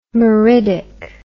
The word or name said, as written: meridic